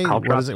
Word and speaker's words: caltrops: Caltrops. What is it called? – I think it's pronounced caltrops, C-A-L-T-R-O-P-S.